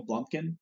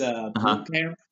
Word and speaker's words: Blomkamp: I always call him Neil Blumkin, but it's not Blumkin. It's Blomkamp, I think, who did District 9, when he was getting ready to do Halo.